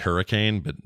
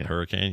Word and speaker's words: Hurricane: It's spelled like hurricane, but you don't say it hurricane. You say hurricane.